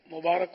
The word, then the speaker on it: مبارک: آپ سب کو بھی مبارک ہو۔ اس کا تلفظ مبارک را پر زبر آئے گا، را کے نیچے زیر غلط، مبارک عام طور پہ لوگ بولتے ہیں عید مبارک، تو عید مبارک نہیں، عید مبارک.